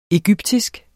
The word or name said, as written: egyptisk